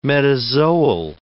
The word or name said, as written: metazoal